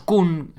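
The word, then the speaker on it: !Kung: Están los Kun San, que bueno, Kun se escribe K-U-N-G y San pues, como San Mateo, ¿no? Igual. Pero encima, antes del Kun va como una exclamación ahí.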